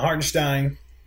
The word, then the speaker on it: Hartenstein: We talked about Hartenstein, and I'm going to say his name properly now. Hartenstein, you saw him catch the ball in the high post and make a couple backdoor plays.